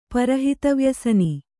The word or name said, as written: ಪರಹಿತವ್ಯಸನಿ